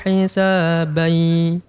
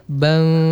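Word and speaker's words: hisabai (حِسَابًا): Pada kalimat fasaufa yuhasabu hisabai. Jadi bacanya bai. Tadi kalau enggak salah saya dengar tadi bang gitu, Ibu.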